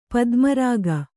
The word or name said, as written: ಪದ್ಮರಾಗ